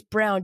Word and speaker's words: Braun: Brown is it Brown? I think it's Brown, just spelled like Braun.